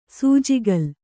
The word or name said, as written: ಸೂಜಿಕಲ್ಲು